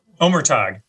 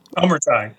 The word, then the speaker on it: Omurtag: Omurtag. – Oh, Omurtag. No. – Omurtag. Omer, like Omer. Omer. Omurtag.